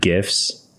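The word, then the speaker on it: GIF: And I figured out how to make animated GIFs. And yes, I do pronounce it with a hard G.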